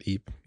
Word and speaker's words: EAP: I call it EAP. I don't know how folks pronounce this, EAP. – That's how I pronounce it, EAP, yeah.